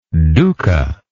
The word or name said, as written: dukkha